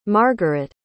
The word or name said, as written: margaret